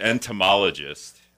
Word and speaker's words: entomologist: He's an entomologist. I've got that word down. I can almost spell it. – You're pronouncing it correctly. That's right.